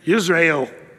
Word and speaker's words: Jezreel: Again, this has to do with the play on words, but Jezreel, we say Jezreel, but how would you say it in Israel, even to this day? You'd say Israel. Does that sound like another word? It sounds like Israel. It's almost the same.